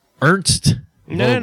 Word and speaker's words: Ernst Vogel: But Ernst... Vogel. – No. Say it right. – Ernst Vogel. – Perfect.